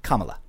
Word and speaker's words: Kamala: No, you didn't. It's think of a comma. – Kamala.